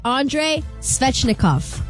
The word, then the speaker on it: Andrei Svechnikov: Andre Svechnikov.